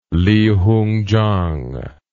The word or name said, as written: Li Hungchang